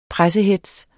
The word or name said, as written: pressehetz